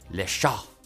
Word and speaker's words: Le Chat: Mistral is also rolling out a multilingual chatbot called Le Chat, which is masculine French for the cat, but which will likely be mispronounced by hapless English speakers as the feminine word for cat, which is, of course, extremely vulgar slang.